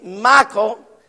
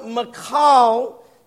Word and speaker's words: Michal: I've always pronounced it Michael, but Bible words call her Michal.